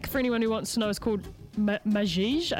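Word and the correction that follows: Maxixe: I think is how you say it. Majij Samba Groove, from an album of the same name. – Machiche. – Machiche. – Yeah, because like, the Portuguese is like different.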